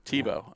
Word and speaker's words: Tebow: Yeah, Tebow rhymes with show.